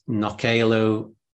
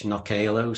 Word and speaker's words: Knockaloe: For anybody who's perhaps interested, the Nokalo Visitor Centre on the Isle of Man, their website is nokalo.im. It's a funny word. It's a mixture of Manx and the Norse Viking language, Nokalo. So it's spelled K-N-O-C-K-A-L-O-E, Nokalo.